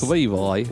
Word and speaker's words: Levi: Irgendwie futuristic, Mika Levy, Levy. She's British, so it's so it's Levi.